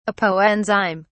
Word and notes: apoenzyme: British English

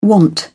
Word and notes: want: British English

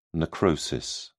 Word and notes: necrosis: British English

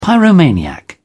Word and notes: pyromaniac: British English

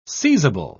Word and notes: seizable: British English

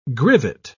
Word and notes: grivet: British English

